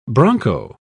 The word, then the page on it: broncho: British English